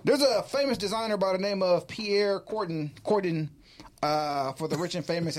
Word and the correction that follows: Cardin: Did I say his name wrong? Cardin. – There you go. – Cardin. – Yes.